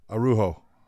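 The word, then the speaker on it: Araújo: It's Arojo, right? – Arojo. – Arojo. Yeah. Okay.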